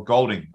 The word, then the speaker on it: Goulding: We worked out with about 8 minutes left when Chris Goulding hit a three, or Goulding. I need to start saying Goulding.